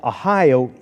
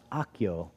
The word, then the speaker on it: Ahio: They carried it on a new cart from the house of Abinadab, and Uzzah and Ahio, actually that name is pronounced Akio in Hebrew, Akio.